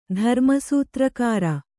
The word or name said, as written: ಧರ್ಮಸೂತ್ರಕಾರ